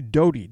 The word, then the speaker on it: Doty: Doty,